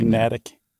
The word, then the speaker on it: Natick: It's got to be Natick. – Natick? – Yeah, totally.